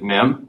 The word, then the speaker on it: Mihm: My Twitter handle is just my name, David Mim. Last name is spelled M-I-H-M.